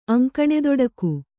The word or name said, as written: ಅಂಕಣೆದೊಡಕು